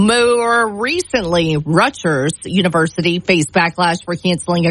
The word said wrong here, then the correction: Rutgers: You hit a soft G instead of a hard G. Rutgers. – Rutgers.